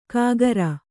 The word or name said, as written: ಕಾಗರ